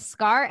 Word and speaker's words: Skaar: Arkham O with Scar, S-K-A-A-R. Am I saying that right? – I believe so, yeah.